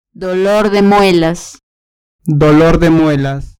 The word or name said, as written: dolor de muelas